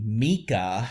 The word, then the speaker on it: Mika: Certainly Micah is... – Excuse me, Mika. – Okay, that's not how that name's pronounced.